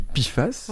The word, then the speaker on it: PFAS: Alors, ça s'écrit PFAS en majuscule, on dit PFAS. – Oui. – Voilà, je l'aurais appris aujourd'hui. – On le prononce PFAS.